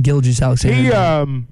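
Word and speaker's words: Gilgeous-Alexander: Gilgeous Alexander.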